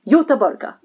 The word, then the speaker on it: Göteborg: Nay, Gothenburg. So we pronounce it Göteborg.